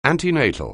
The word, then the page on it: antenatal: anglais